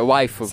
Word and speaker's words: waifu: A última sílaba é a sílaba tônica, é tipo, é waifu.